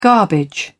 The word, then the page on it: garbage: British English